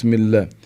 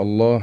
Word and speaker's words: الله: الله، حتى مذكور في كتب العلماء هذه الألف التي تلفظ ولا تكتب بين اللام والهاء الله لابد منها.